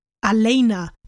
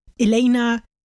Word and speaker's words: Alena: Aber es ist Alena und nicht Elena, Elena, irgendwas.